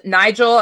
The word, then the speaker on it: Nigel: He pronounced Nigel as if it rhymed with nickel, a 5-cent coin.